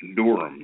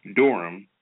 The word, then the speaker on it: Durham: Durham.